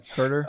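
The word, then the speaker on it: Huerter: Hooter, however you say his name. – Herter, Kevin Herter.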